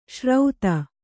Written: ಶ್ರೌತ

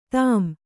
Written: ಧಮ್ಮ

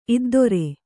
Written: ಇದ್ದೊರೆ

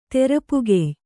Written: ತೆರಪುಗೆಯ್

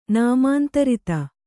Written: ನಿಮಂತ್ರಿತ

